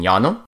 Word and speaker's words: llano: A double L is pronounced like a Y, such as in llano.